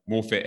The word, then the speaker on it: Morphett: So Brad and Shane Rawson from Morfit Air. Have I pronounced the company name right, by the way? – Yeah, cool.